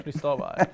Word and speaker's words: Cristobal: Cristobal.